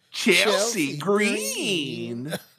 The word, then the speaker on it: Chelsea Green: No, you're not saying it right. It's Chelsea Green.